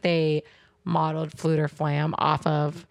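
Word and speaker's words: Fflewddur Fflam: His name is Fluter Flam.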